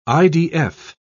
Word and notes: idf: British English

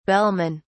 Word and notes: bellmen: British English

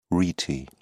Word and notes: rete: British English

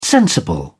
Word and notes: sensible: British English

